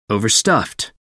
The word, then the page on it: overstuffed: American English